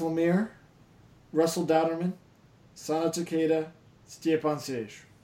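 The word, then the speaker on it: Stjepan Šejić: Stjepan Sejic.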